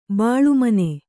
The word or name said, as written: ಬೆಳುಮೀನ